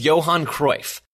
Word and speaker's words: Johan Cruyff: His name rhymes with Bohan Royf. Oh, you got it. It was Johan Cruyff.